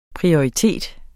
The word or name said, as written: prioritet